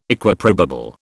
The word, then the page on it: Equiprobable: British English